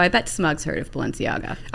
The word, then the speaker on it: Balenciaga: Balenciaga. I'll pronounce it for you. – There it is. – Balenciaga. – Yeah. Balenciaga.